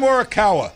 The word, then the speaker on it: Morikawa: I said Colin Marikawa. Okay? – But his name's Colin. – Colin Marikawa. – No, Morikawa. It's Colin Morikawa.